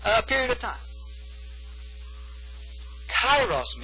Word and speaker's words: kairos: But this word is kairos. We would spell it K-A-I-R-O-S, kairos.